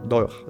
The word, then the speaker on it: Doha: Doha, sí, Doha se pronuncia entre la administración Trump y los talibanes,